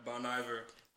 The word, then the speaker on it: Bon Iver: Yeah, Bon Iver.